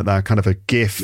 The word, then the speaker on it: GIF: So it's the G should be pronounced with a G sound because of graphics. So it's GIF.